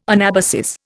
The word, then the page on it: anabasis: Indian